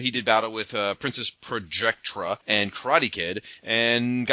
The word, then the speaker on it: Projectra: He challenged Princess Projecta, I can't say her name for some reason. – We call that Projectra.